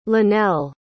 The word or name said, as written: lonnell